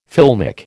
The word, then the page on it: filmic: American English